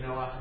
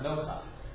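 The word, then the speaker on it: noach (נֹחַ): Now, but we think, this we know, because we pronounce the fact, so we of course say noach not nocha.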